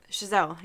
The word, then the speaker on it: Chazelle: And I just, like, and someone like, how do you say his last name? I always forget. – Chazelle, yes. I accidentally always want to say Chappelle.